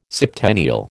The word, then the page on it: septennial: American English